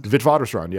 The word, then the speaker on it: Witwatersrand: And is this, if I'm saying it correctly, Witwatersrand? – Witwatersrand, yeah.